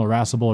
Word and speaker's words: irascible: Like, one way is you can think that irascible almost has the word irrational, kind of rhymes with irrational, irascible, irrational, someone acting irrational might act irascible.